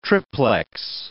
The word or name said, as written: triplex